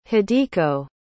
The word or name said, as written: hideko